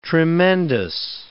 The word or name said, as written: tremendous